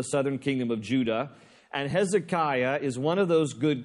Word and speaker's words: Hezekiah: And the guy on the throne at this particular time, from chapters 18 through 20, is a guy by the name of Hezekiah. Now, in Hebrew, his name is pronounced Hizkiah, and it translates Yahweh, or God, is strength.